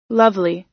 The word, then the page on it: lovely: American English